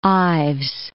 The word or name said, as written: Ives